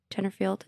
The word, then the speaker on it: Tenterfield: Tenterfield? I don't even know if I'm saying that right. – No, that sounds right. Probably the one thing in Australian that's pronounced how it's said how it's written.